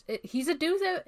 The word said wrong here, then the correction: Deus: He's a. Or I always say this wrong. It's like. – Deus. – He's just there to, like. – Yep. – Deus, that's the one.